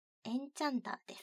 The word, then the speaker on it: 気候魔術師: ガンガン浄化というスクエアエニックスさんの雑誌で漫画を描かれている方で、有名なのはエンチャンターですかね。気候魔術師と書いてエンチャンター。